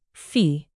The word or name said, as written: fee